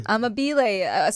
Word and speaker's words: Amabile: Amabile. Supposedly that's how you're supposed to pronounce Joe's last name according to a true Italian.